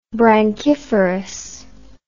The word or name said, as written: branchiferous